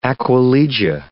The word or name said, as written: aquilegia